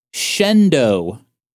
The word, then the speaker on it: Shenandoah: So in our last episode, I mentioned JCPenney opened some stores in Ely, Nevada and Shenandoah, Pennsylvania. Apparently, that's not how they're pronounced. It's actually Ely, Nevada. – Uh-huh. – And Shendo, Pennsylvania. – Okay. – Ely, I can understand. – Uh-huh. – E-L-Y.